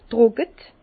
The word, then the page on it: trocke: Bas-Rhin; Herrlisheim